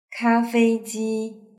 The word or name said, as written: kafeiji